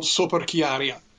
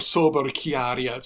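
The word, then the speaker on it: soperchieria: The whole structure of Italian honor and the system of dueling is based on a word called soperchiaria, or soberchiaria, sometimes pronounced, and it is equality.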